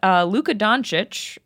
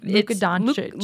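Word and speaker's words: Luka Dončić: Luka Doncic, which is...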